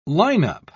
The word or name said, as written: lineup